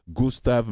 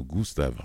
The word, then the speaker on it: Gustav: Il va diriger l'Orchestre symphonique de Montréal dans la symphonie numéro 4 de Gustav Mahler. J'ai prononcé en allemand Gustav, en français on dit Gustav.